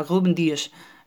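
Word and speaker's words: Rúben Dias: Eh, lo digo así porque es la pronunciación correcta, Ruben Díez.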